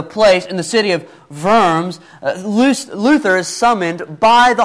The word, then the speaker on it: Worms: Now, Worms is spelt with a W, but in German, W is a V, okay? Volkswagen. Anyways, Diet also, by the way, doesn't mean diet as in, you know, you need to have a more crunchy diet or whatever.